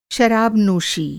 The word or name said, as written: नोशी